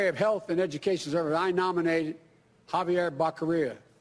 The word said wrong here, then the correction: Becerra: Javier Becerra, excuse me.